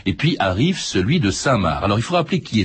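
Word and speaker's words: Cinq-Mars: Je précise aussi que on dit parfois 5 Mars, parce que ça se prononce comme ça, c'est INQ comme le chiffre et Mars comme la planète, mais ça se prononce Saint-Marc, François Zéril Descheimer.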